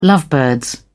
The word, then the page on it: lovebirds: British English